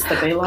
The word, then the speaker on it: Stabilo: Stabilo? – Oh, yeah.